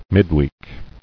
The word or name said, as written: Midweek